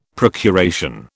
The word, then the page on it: procuration: British English